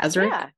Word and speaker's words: Sazerac: So that's how you pronounce it. Right? Yes. – Sazerac. Sazerac. Yeah. – Sazerac, yeah.